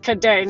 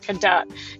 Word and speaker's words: Cadet: So I must apologize to Dr. Akilah Cadet for not knowing how to pronounce her name, which is pronounced Cadet, not Cadet. It's French, people.